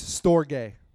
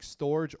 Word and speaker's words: storge: S-T-O-R-G-E. Storge. S-T-O-R-G-E. I thought it was like storage or storage. There's my English language trying to do it. But it's storge. So it's like S-T-O-R-E-G-A-Y is how you pronounce it. Storge. But it's spelled S-T-O-R-G-E.